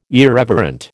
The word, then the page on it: irreverent: American English